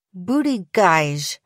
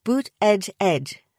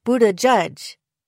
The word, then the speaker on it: Buttigieg: On his Twitter, Buttigieg himself suggests that people should pronounce it like Bood-eh-jedge, though his husband offers the alternative Buddajudge.